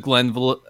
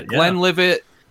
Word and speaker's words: Glenlivet: I usually do, you know, the Glenlivet? How you pronounce it. – Glenlivet. Yeah, Glenlivet.